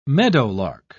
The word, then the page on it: meadowlark: British English